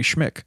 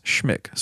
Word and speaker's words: Schmich: And it was actually a column from the Chicago Tribune by writer Mary Schmick. I hope I'm saying that right. S-C-H-M-I-C-H. The best I can figure how you would say that name is Schmick. So if I'm saying that wrong, we're just going to pretend I'm saying it right.